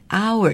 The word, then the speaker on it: hour: 它的发音虽然有个 H，H 是不发音的，我们念 hour 就是一小时。